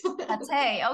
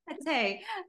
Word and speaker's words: Patè: Patè, okay. – Yes. – Patè. Okay, I forgot to ask you, but I pronounce it right. – You pronounce it absolutely right.